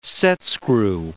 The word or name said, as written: setscrew